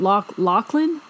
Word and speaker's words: Loughlin: Yeah, well, they actually had these two daughters, Lori Loughlin. – Laughlin. – Why am I having such a hard time pronouncing her name?